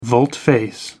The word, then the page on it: volte face: American English